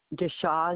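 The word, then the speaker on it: Deschamps: Cindy, C-I-N-D-Y. Deshaw is my last name. It's spelled like Des Champs. It's D-E-S, capital C-H-A-M-P-S.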